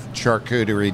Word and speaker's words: charcuterie: This is it pronounced charcuterie? – Charcuterie. – Charcuterie. – At least I think so. – How do you say am I correct? – Charcuterie. Charcuterie. Is correct, yeah.